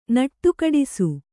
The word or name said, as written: ನಡೆಗೆಡಿಸು